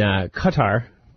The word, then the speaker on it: Qatar: Coming back after the break, a little bit more on ETF news and Qatar. Is how you really say it. It's spelled Q-A-T-A-R.